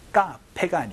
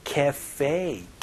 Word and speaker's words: cafe: 카페가 아니고 cafe, cafe 이렇게 발음하니까 혹은 커피숍이라고 발음해주시면 좋겠네요.